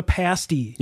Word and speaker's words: pasty: So the next one I have, it's, you have to pronounce it correctly. It is a Northwoods delicacy known as the pasty. – Yes. – Do not call it the other word because it has another meaning completely.